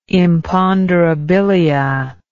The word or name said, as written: imponderabilia